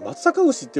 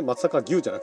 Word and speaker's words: 松阪牛: あとは松阪牛の日、松阪牛って読むんですね、松阪牛じゃなくてね、松阪牛の日ですって。